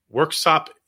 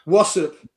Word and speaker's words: Worksop: I read that you were born in Worksop, England. Am I pronouncing that right? – Well pronounced. Well pronounced. Yeah, Worksop. Yeah. Wassup, they say in Yorkshire.